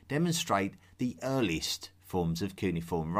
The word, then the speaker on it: cuneiform: Cuneiform. I call it cuneiform, but you will also hear it pronounced as cuneiform.